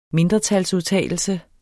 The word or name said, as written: mindretalsudtalelse